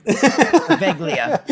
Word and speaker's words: Poveglia: Poveglia. – Yes. Is that how you say it? – That's what I think. I'm Italian. So. – Okay. Poveglia.